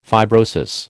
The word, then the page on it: fibrosis: American English